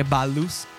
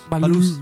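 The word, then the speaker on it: Ballús: magari Ballus o Balus. Cosa sia Balus? Balus, Balus con l'accento sulla U.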